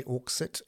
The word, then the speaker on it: Auxit: Uh, in fact, yesterday's decision has been described as Auxit, A U X I T, but like Brexit. Although X, when you say Auxit, it can also be pronounced X as an SH, Auxit.